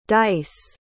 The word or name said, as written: Dyce